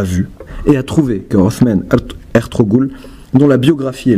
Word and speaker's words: Osman: Othman Ertuğrul. Bon, je prononce mal, note de traduction, c'est Osman Ertuğrul.